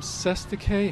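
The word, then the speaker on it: cestui que: You use the term sestike. How do you spell that? – It's C-E-S-T-U-I-Q-U-E.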